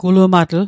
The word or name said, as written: gulumatl